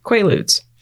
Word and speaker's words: Quaaludes: Quaaludes. Am I saying that right? – Yep.